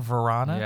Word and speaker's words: Vrana: Carlson sets up Vrana, is that how you say it? Vrana? – Yeah, Vrana.